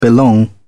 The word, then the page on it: belong: British English